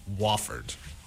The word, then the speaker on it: Wofford: After a home loss to Wofford. I think I pronounced that wrong. – No, you got it.